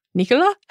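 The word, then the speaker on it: Nicolas: his name is pronounced Nicola.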